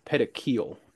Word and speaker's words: petechial: It's pronounced petechial, if you were Okay.